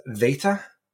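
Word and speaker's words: Veta (Βήτα): Also, Beta, or if Rhea was here, she would correct her pronunciation of the second letter of the Greek alphabet, which has a kind of hard V sound. So it's Veta, like with a V.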